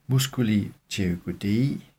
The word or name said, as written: mm. pterygoidei